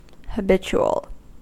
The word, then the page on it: habitual: American English